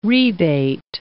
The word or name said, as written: rebate